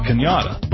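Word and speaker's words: Cañada: Remember, sounds like Kenyatta, but spelled like Canada.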